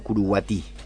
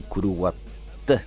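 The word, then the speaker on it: Curuguaty: que se llama Curuguatí. Los guaraníes dicen Curuguaté, pues viste que no pronuncian Curuguaté.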